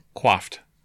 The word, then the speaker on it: quaffed: Quaffed. – I like quaffed. – Yeah. – I'm going to continue saying quaffed. – Quaffed rhymes with wafted, like.